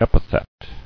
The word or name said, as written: Epithetical